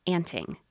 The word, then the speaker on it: anting: But there is one form of bird body care that may have no equivalent in our health spas: anting. Anting, spelled like it sounds, A-N-T-I-N-G, is a bizarre form of bird behavior that has often been observed but is not well understood.